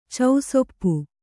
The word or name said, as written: ಚೌಸೊಪ್ಪಿ